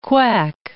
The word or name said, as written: quack